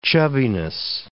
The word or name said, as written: chubbiness